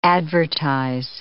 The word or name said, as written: advertise